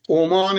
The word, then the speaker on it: عمان: ولی این درسته، کشور تلفظ کشور عمان، عمان بدون تشدید.